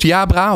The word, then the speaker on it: Jabra: Ja, zeg ik het goed? Jabra? Of moet ik het meer als Jabra zeggen? Nee, Jabra. Oké, heel goed. Heel goed. Als in uh, Jabra Kadabra.